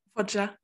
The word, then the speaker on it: Foggia: And as well, the like gap between both of the riders to Dennis, I'm going to say his last name wrong, Foggia? – Foggia, yeah.